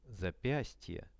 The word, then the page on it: zapiastie: Russian